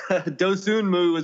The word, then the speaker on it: Dosunmu: Dosunmu. – Dosunmu is what I'd say.